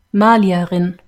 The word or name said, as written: malierin